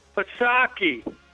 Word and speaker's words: Psaki: Jesse, I'm sick of you radio guys saying it wrong. It's Psaki. You got it? You know who I'm talking about? That redheaded... Oh, God, she's homely. Psaki. Say it right. Psaki.